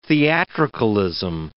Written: theatricalism

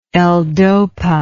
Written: ldopa

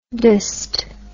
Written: DST

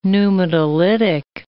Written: pneumatolytic

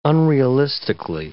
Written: unrealistically

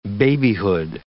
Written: babyhood

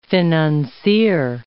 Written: financier

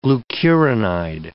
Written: glucuronide

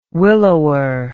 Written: willower

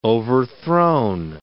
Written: overthrown

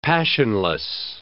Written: passionless

